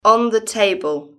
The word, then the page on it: on the table: British English